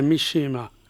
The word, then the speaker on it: Mishima: Mishima, dove va l'accento intanto? – I giapponesi non hanno accenti, quindi okay. Per noi è difficile, è Mishima.